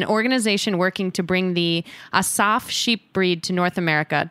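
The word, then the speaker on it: Assaf: But Asaf, listeners, we'll put it in the show notes, but it's A-S-S-A-F.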